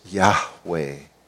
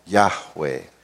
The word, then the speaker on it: Yahweh: but we've given it uh, pronunciation so we can understand it, and we say it's Yahweh. Yahweh.